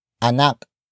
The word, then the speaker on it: anak: May anak ba si Julia? May anak ba si Julia? May anak ba si Julia? It means, does Julia have a child? Word for word it's, may, anak, ba, si, Julia.